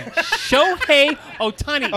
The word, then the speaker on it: Shohei Ohtani: How do you say his name? – Shohei Ohtani.